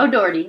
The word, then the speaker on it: O'Doherty: O'Doherty. I feel like I'm saying that wrong. – O'Doherty. That's right.